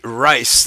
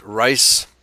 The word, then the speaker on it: Reis: Reiss. That's the German pronunciation, R-E-I-S, Reiss. Hasn't been anglicized.